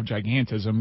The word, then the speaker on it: gigantism: And, I mean, does he clinically have giganticism? – I don't know. – Is that even, am I even pronouncing the disease correctly? – No, I think he's too lanky to have gigantism, because typically, like, Andre the Giant was way shorter than Bob.